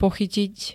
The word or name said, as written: pochytiť